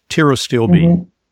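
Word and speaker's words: pterostilbene: It's spelled like pterodactyl, P-T, tiro, patiro, but the P is silent. So it's tirostilbene.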